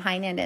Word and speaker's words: Heynen: You can find me on Instagram at Heinen Counseling and Coaching. My last name Heinen is spelled H-E-Y-N-E-N Counseling and Coaching on Instagram.